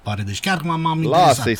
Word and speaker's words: Paredes: Și mai spune cineva că se pronunță Paredes, nu Paredes.